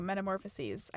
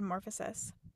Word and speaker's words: Metamorphoses: I pronounced it wrong. I'm so sorry. Metamorphoses. I said morphosis.